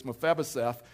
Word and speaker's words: Mephibosheth: It's the story of Mephibosheth. And so I've done so good in all the services, and I just mispronounced it, but it's Mephibosheth.